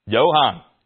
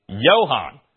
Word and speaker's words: Johann: Johann. Because in German, you pronounce it like a Y, but you spell it with a J. Johann.